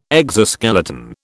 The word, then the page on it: exoskeleton: British English